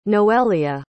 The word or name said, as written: noelia